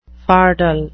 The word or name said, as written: fardel